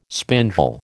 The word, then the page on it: spindle: American English